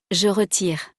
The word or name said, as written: je retire